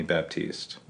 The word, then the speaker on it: Baptiste: and then I started just shortening it to Baptiste all the time because it's easier, you know, people know Baptist, whatever, you just add an E at the end and change up your pronunciation.